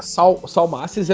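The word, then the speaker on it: Salmacis: Salmasses, é assim que se pronuncia? – Sim.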